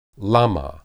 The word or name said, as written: lamah